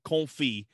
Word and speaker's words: confit: Yeah, confit. It's confit. Spelled oui. Confit. Yeah. So that's, us Americans have a problem with it. But anyway, basically, yeah, it's, sounds like confit freedom.